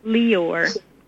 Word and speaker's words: Lyor: Well, the echo, echo, echo that's in charge of this, that came on board, is Lior Cohen, L-Y-O-R. He claims it's pronounced Lior.